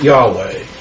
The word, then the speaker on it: Yahweh: Um, his most special name was Yahweh. You know, was that's how they pronounced it.